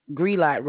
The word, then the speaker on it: Grelot: And it's 6280 Greg Light Road, which is Greelight Road. I'm sorry. It's spelled G-R-E. L-O-T Road, Mobile, Alabama.